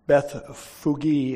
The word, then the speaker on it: Bethphage: Bethphage is how it's actually pronounced, means house of unripe figs.